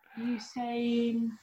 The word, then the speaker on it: Usain: Usain. – Remember it rhymes. – Insane.